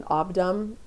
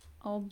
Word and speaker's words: Obdam: Obdum is absolutely stunning. – How would one spell that for one's shopping basket? – O-B-D-A-M. – Obdum.